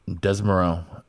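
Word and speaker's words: Desormeaux: I think Michael Desmoreau is going to kill it. I think I said that right. – Yeah. Desmoreau.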